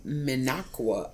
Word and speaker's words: Minocqua: Min- Minocqua.